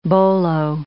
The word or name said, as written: bolo